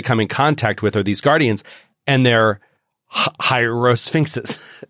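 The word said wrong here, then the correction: Hieracosphinx: Is that how you pronounce it? – Hierarchosphinx. – Hierarchosphinx.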